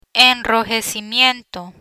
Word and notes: enrojecimiento: Spanish

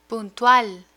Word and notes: puntual: Spanish

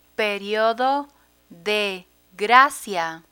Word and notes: periodo de gracia: Spanish

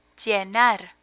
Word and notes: llenar: Spanish

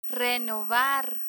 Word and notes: renovar: Spanish

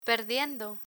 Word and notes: perdiendo: Spanish

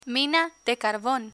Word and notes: mina de carbon: Spanish

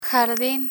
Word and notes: jardin: Spanish

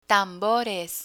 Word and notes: tambores: Spanish

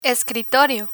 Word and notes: escritorio: Spanish

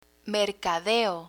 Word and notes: mercadeo: Spanish

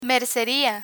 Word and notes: merceria: Spanish

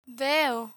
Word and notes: veo: Spanish

